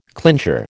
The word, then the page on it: clincher: American English